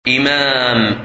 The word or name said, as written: Imam